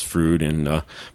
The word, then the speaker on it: Froude: You say it the way Bill and Ted pronounce Froude, but it's actually spelled Froude.